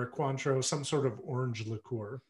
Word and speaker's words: Cointreau: Cointreau, yeah. – Cointreau, yeah.